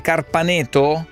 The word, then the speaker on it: Carpaneto: Carpaneto, ho detto bene, Carpaneto? – Sì.